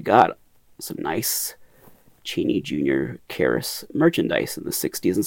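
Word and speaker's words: Kharis: I usually say Karis, because it rhymes with Claris from the Evan Costello movie.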